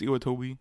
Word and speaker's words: Iwatobi: Is that how you say it? – Iwatobi? – Iwatobi? – Iwatobi. I think it's Iwatobi.